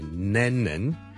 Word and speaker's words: Lennon: 第一个字呢就是人名来的 John Lennon John Lennon John Lennon 一个英国的很出名的歌手作曲家 Lennon L-E-N-N-O-N 第一个是 L 音所以不要将它读成 N 音不是 Lennon 是 Lennon Len Le Le Le Le Le Le Le Le Le Le Le Le Len 后面那个才是 N 音 so,